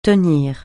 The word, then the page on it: tenir: francais